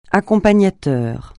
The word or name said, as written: accompagnateur